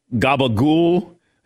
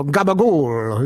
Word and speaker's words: gabagool: You got to say it like Tony Soprano, but I know you've never seen this one. He used to go gabagool. He used to really get into that word on the show.